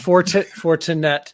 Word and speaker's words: Fortinet: The Fortinet, is that how you say it? But is it the Fortinet? I'm probably saying it wrong. – Fortinet. Yeah, Fortinet. I'm gonna go ahead and early and disagree with you, and we're gonna go ahead and start the show off like that.